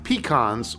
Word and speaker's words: pecan: Up here in Lancaster, the Amish pronounce it pecan, as in peeking around the corner.